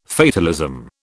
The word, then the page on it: fatalism: British English